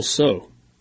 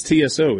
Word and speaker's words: Tso: Is it General Tso or General So? – I say Tso. I mean, there's a T in there that's not getting pronounced either way. So who cares? – Where do you get the A, though? I mean, it's TSO, isn't it? – It's a long O. Tso. Like SOW, a female pig is a sow. There's an O in there.